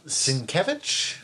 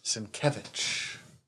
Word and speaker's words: Sienkiewicz: Okay, so tell me how to say the name again. Bill Sienkiewicz? – Yes. – Sienkiewicz.